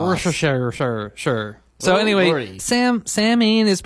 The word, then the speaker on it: Samhain: it's spelled S-A-M-H-A-I-N, which you would think would be Samhain. – Mm-hmm. – But it's actually pronounced Samhain.